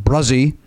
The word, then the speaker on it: Brazee: It's pronounced Bruzzi, since I know you're sensitive about such things. B-R-A-Z-E-E, Bruzzi. I would say that's either Brazy or Brazzy. – Yeah. – But he tells us it's Bruzzi.